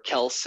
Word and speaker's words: Kelce: I thought for some reason that Travis Kelce, or Kels as he says it's correctly pronounced, thought that he would fall to 12 overall.